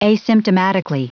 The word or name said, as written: asymptomatically